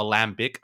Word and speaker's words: lambic: Is it Lambic or Lambic? I don't quite know how to pronounce it. – It's Lambic.